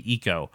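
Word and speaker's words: Ico: We always said Iko because that's a very Americanized way of saying it. But if you think about it, like in terms of Japanese pronunciations, I's are pronounced E. So it'd be Iko.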